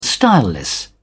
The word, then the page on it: styleless: British English